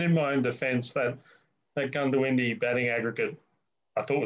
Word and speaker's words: Goondiwindi: It's O, but it's pronounced with a U, so it's Goondiwindi.